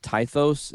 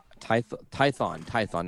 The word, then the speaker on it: Tython: We got, what is it, Tythos? Is that how you say it? Tython, not Tythos, yeah.